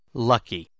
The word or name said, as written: lucky